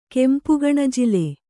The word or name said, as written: ಕೆಂಪುಗಣಜಿಲೆ